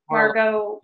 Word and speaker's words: Mar-a-Lago: And he also kicked him off I always say it wrong. What is it, Margo? Mar-a-Lago. – Banned him from Mar-a-Lago.